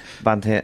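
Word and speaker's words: Bánh tét: You talked about now, correct me if I'm wrong, my pronunciation is terrible, but Ban Te and Ban Chong? – You've done very well, actually. For a 5-second class, you've done really, really well.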